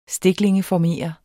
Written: stiklingeformere

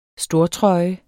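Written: STORTRØJES

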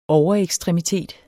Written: overekstremitet

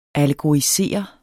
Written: allegorisere

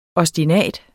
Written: OSTINATER

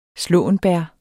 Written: slåenbær

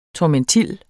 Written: tormentil

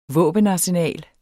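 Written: VÅBENARSENAL

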